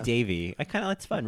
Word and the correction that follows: Davies: No. With the S. Ricky Davies. – Ricky Davies. Oh. – Yes. But it was spelled like Davis.